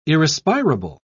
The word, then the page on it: irrespirable: British English